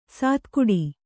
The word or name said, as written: ಸಾತಗಡಿ